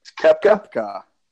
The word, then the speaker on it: Koepka: Koepka. – Like it's. – I always want to call him Koepka. – Yeah, just take the O out. Yeah. – Right, so we got Brooks Koepka at number two,